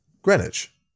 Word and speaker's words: Greenwich: What is its pronunciation? – Greenwich.